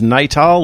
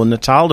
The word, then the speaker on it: Natal: And I think it was probably around about 12 months ago, they had a project, they being Microsoft, had a project called, I think it was Natal or Natal, depending upon which accent you wanted to pronounce the A on.